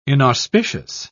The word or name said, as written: inauspicious